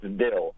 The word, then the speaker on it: devil: And one of the things I came across is that the V sound in the middle of the word would typically be dropped, and you see that expressed like, what error, E apostrophe ER, or devil would be pronounced dill,